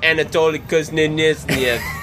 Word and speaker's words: Anatoly Kuznetsov: It was just Anatoly Kuznetsov. – That's exactly how you say his name.